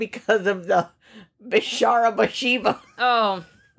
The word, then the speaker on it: Bathsheba: Bathsheba. How do we say that? – That is how you say it. Bathsheba.